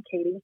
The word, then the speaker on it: Cadiz: I'm currently living in Katy's, Kentucky, a little small town, and everybody mispronounces the name because it's spelled C-A-D-I-Z, but it's pronounced like a female name, Katie.